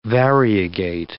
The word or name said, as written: variegate